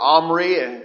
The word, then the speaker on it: Omri: It was indeed his father Omri, or Omri, depending on how you want to pronounce it,